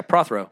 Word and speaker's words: Prothero: I, um, pro, uh, pro, uh, Prostero, uh, Stephen, um, uh, another. Oh, Prothero. pro- yes. Yeah, Prothero.